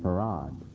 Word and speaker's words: Fard: He told his followers to call him Farad. So the spelling, the pronunciation of F-A-R-D is Farad, though it's not spelled that way. It's Farad.